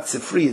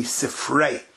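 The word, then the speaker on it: Sifrei (ספרי): Really, it's not Sifri, it's really Sifrei. But the Oilim pronounces it Sifri.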